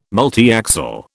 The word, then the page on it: multiaxial: British English